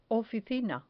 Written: oficina

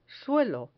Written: suelo

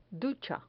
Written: ducha

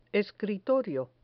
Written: escritorio

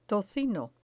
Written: tocino

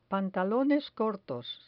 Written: pantalones cortos

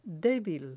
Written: debil

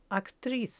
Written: actriz

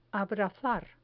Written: abrazar

